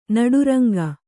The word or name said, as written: ನಡುರಂಗ